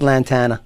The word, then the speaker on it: Lantana: You have to say it right. – What's that? – Say Lantana. – What? Lantana. It's Lantana. – Lantana. – Say banana.